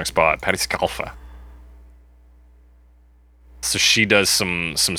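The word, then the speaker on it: Scialfa: Yeah, I think it's just Scalfa. Scalfa, she. – Because I ju- I looked it up, and uh, that was the pronunciation. I think the I is just a just to throw you off.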